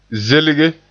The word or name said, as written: zelge